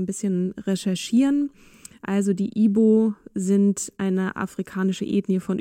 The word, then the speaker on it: Igbo: Ihre Familie gehört zur Bevölkerungsgruppe der Ibo, es wird Igbo geschrieben, aber das G spricht man nicht mit. Das ist eine, eine Bevölkerungsgruppe, die vor allem christlich ist.